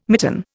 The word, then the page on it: mitten: Indian